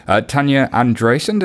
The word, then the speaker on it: Tanya Andreasyan: Tanya Andreessen, did I say your name right? – Yeah.